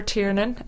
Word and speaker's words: Tiernan: It's at Eleanor Tiernan, ELEANOR TIERNAN.